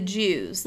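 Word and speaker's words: Juwes: The Jews. That's how it's pronounced, but like I said, it's J-U-W-E-S.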